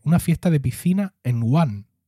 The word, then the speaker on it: Wuhan: Wuhan. Wuhan. – Estaban haciendo Pensé que estabas hablando de la isla de Wuhan.